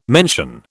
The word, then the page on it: mention: British English